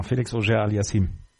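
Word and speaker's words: Felix Auger-Aliassime: Felix Auger Aliassime.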